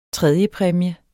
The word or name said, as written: tredjepræmie